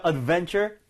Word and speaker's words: adventure: 자, adventure 맞습니다. 이게 바로 모험심이고요. adventure 발음 어디 강세 줄것 Adventure. – 같아요? 오케이. 자, adventure. V-E의 강세를 올리는 게 맞아요.